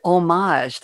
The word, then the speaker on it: homage: So it looks like it was an homage. That's the French pronunciation, an homage to so many people.